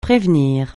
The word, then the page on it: prévenir: francais